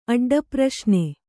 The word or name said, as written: ಅಡ್ಡಪ್ರಶ್ನೆ